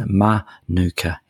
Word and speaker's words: Manuka: They pronounce pronounce it Manuka in Canberra.